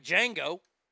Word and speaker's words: Django: It's not like Django, where the D is silent.